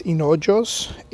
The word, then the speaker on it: Hinojos: My name is Gustavo Hinojos. If you don't know how to, you know, pronounce it or to write it down, it's Hinojos, H-I-N-O-J-O-S, Hinojos.